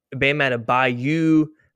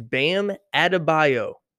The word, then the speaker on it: Bam Adebayo: Jimmy Butler and Bam Adebayo, who nobody can say his name right. Bam Adebayo, Bam Adebayo. His name is Bam Adebayo.